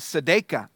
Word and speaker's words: tzedakah: The word for righteousness there is a Hebrew, and I don't speak Hebrew, but I can, you know, pronounce things that the Bible dictionaries tell me how to pronounce, but the Hebrew word for this is tzedakah,